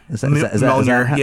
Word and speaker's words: Mjolnir: But the fact that they completely hid the fact that Jane was dying and that she was using, you know, Mjolnir. Is that how you pronounce it? Mjolnir? – Mjolnir, yeah.